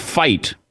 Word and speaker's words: Fite: And you had the manager, the assistant manager, Craig Fite, I believe is how it's pronounced, F-I-T-E.